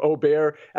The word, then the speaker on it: Åberg: And we see both Minwoo Lee and Ludvig Åberg here, or Åberg, as it's properly pronounced, at 20-1.